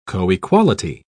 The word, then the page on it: coequality: British English